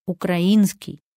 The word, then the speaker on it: украинский: Словари закрепили ударение на «и» – «украинский».